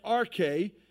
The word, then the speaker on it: arche: It is the Greek word arche, pronounced arche, and it literally means a commencement or a beginning of something, the origin of something.